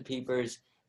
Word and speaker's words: Pieper: And we're so excited to have him on because we're going to be talking about philosophy today, specifically the book, Josef Pieper's. Am I saying that right, Peter? – Yeah, that's right. – Okay. Yeah, Josef Pieper's.